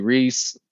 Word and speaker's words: Reese: And then my Twitter's Rockley Reese, R-O-C-K. L-E-E-R-E-E-S-E.